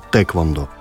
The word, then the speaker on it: тхэквондо: Ну, корейцы э, говорят, как если внимательно слушать, тэквондо. По-английски э, звук «э» пишется как «ае».